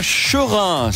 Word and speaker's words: Cherin: On est à Cherin ce matin et ce n'est pas un jeu de mots avec serein, ça s'écrit C-H-E-R-I-N.